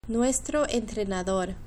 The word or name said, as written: nuestro entrenador